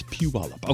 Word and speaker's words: Puyallup: It's not Puyallup, it's Puyallup.